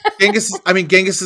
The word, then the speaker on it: Genghis: Genghis is the way that it's pronounced, like that it's pronounced in English when it gets romanized into English.